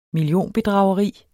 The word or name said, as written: millionbedrageri